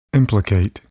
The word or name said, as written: implicate